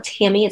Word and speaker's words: Tami: And so if you go to chat with Tami, it's chat with T-A-M-I,